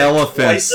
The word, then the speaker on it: elephant: I'm sorry, can you give pronounce elephant one more time for me? Because you botched it elephants.